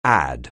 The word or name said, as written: add